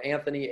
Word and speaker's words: Anthony: So Anthony, A-N-T-H-O-N-Y. Last name is Coletti, C-O-L-E-T-T-I, and a little underscore at the end.